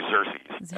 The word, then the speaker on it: Xerces: I think you say Xerces, X-E-R-C-E-S, yes.